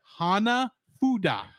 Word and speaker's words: Hanafuda: You said Hanafudu. – Hanafudu. – Hana. Say it with me. – Hana. – Ha. – Ha. – Na. – Na. – Fu. Fu.